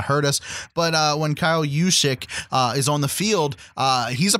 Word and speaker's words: Juszczyk: And then we got this fullback, Kyle, and I'm hoping I'm saying his name right, Juszczyk. – Juszczyk.